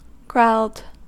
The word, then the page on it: growled: American English